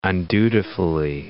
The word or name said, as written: undutifully